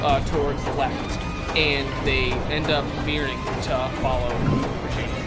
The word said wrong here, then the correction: Rashadium: Oh, Rashadium, not Rashadia. Oh, thank you.